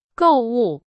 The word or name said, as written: 购物